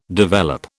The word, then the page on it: develop: British English